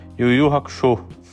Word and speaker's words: Yu Yu Hakusho: Aqui no Brasil também a gente pronuncia como Yu Yu Hakusho.